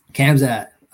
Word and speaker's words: Khamzat: Khamzat.